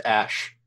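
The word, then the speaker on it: Æ: That's pronounced Ash.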